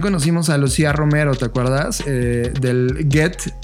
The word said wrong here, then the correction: Goethe: ¿cómo se pronuncia? Get Get Institute. Goethe Institute.